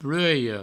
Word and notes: plueia: plueio

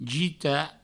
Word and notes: gitar: jita

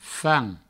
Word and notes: fam: Provençal

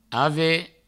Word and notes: aver: ave; Provençal

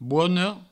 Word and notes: bona: bono

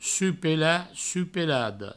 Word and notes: suc-pelat: provençal; suc-pelada; su-pela; su-pelado

